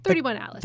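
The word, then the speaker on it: 3I/ATLAS: Is it 31 like dash Atlas, or did you just say 31 Atlas? – 31 Atlas.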